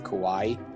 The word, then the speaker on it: Kauai: Forced to jump ship in the waters off, I believe it's pronounced Kauai, which is a Hawaiian island, only to be rescued by a 12-year-old boy.